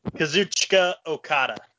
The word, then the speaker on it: Kazuchika Okada: Kazuchika Okada.